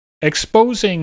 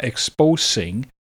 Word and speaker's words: exposing: Exposing has a Z sound where the S is, so it's not exposing, it's exposing. And defenses.